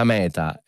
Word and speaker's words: meta: Beh, questa attenzione, perché abbiamo detto sempre che la meta è l'obiettivo, sì. – Ma la meta... non c'è l'accento qui, è un grande escremento di un bovino, sì, la meta.